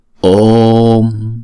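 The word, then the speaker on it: Om: Cho nên chữ ôm này chúng ta đọc là ôm. Đọc chữ O 2 nhịp xong rồi chúng ta mới đóng chữ O đó lại, là chúng ta đọc là ôm. Không? Ôm. Ôm. Không? Đọc chữ O trước cho nó tròn xong rồi chúng ta mới đóng chữ O đó lại. Chứ chúng ta không đọc là ôm. Đúng không? Chúng ta đóng sớm quá thì không đúng.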